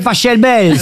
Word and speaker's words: Pachelbel: Cannelloni, c'est Pachelbel. – S'il est Allemand, ça se dit Pachelbel. – Exactement, ouais. Ça pourrait se dire éventuellement Pachelbel, mais on dit en tout cas, ici en France, le canon de Pachelbel.